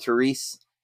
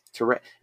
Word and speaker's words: Therese: Even again, I think heightened for the sake of, like, because these men represent, like, men, you know, like that Carol and Therese, or that's not how you say her name, is it? – Therese.